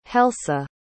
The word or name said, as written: helsa